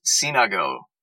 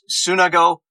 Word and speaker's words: Sunago: The proper pronunciation is Sinago, but he promotes it as Sunago, S-U-N.